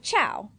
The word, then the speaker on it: ciao: It's spelled C-I-A-O. – Yeah, like chow.